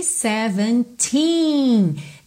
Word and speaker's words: seventeen: Only seventeen. Lembra que a sílaba tônica de todos esses números com teen no final é justamente essa sílaba final, ok? Seventeen. E é compridinho esse som.